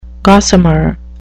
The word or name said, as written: gossamer